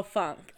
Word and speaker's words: funk: Am I not saying funk? Like am I not there saying we that go. K? – Yeah. – Yeah. – No, the N. You're not pronouncing the N. Yeah. – Oh, funk.